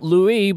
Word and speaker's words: Louis: It's spelt Louis, but for some reason, we call it Louis.